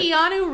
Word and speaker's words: Keanu: I cannot pronounce this name, and I know it's easy. Conal Reeves. I can't pronounce it. – Keanu Reeves. – Keanu Reeves.